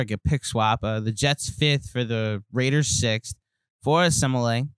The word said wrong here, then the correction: Kelechi Osemele: I'm going to say his name wrong forever. I usually say my offensive lineman's name wrong forever. They're just the guy blocking my quarterback. – Kelechi Osemele. – Okay, there you go. Jesse with the pronunciation for me and for all of you.